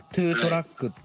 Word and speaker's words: トゥートラック: はい、まあ、レッカートラックっていう、はい、まあね、レッカー車っていう自体はジョーさんから教えてもらって、まあ、資料もあるんですけど、はい、まあ、トゥートラックっていうのが、はい、 TOW だから、トゥーだね。 – あ、トゥーだね。 – 発音は。 – トゥートラック。 – トゥーってあの、バレリーナのトーシューズのつま先と同じ発音だね。